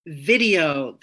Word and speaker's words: VIDIYO: Lego VIDIYO this year. It's spelled V I D I Y O, so like DIY, uh, kind of your own Lego VIDIYO um, I think is the premise behind this.